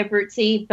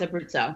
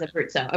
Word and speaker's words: Abruzzo: Yeah, I think I think that more, more Americans say Abruzzi, but it I, I, in Abruzzo it's on the signs. It's like on the traffic signs. It's Abruzzo. Okay. – Everything is Abruzzo.